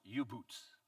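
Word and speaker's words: U-Boot: And you had the German U-boats, and the U-boats are submarines. And it would be how they would pronounce it, U-boots. And so we took it and we said U-boats.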